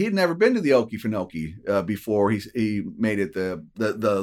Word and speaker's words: Okefenokee: Now, first of all, you have to pronounce it right. It's Okefenokee. You got to get that southernness to you. Okefenokee.